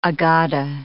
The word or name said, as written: Aggadah